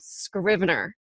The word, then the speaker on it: Scrivener: But when it comes to writing, I have to say that I love this tool called Scrivener. And it's kind of a weird word, so I'll spell it. It's S-C-R-I-V-E-N-E-R, Scrivener.